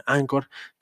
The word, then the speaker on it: Anchor: no sé cómo se pronuncia, se escribe Anchor, se pronuncia Anchor, que les recomiendo muchísimo.